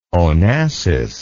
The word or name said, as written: onassis